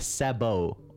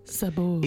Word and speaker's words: sabot: And the French word for the kind of clogs that they wore was, I guess you'd pronounce it a sabot. Or a sabot. – S-A-B-O-T-S.